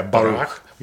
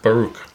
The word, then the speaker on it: Baruch: Yeah, Baruch. – Baruch.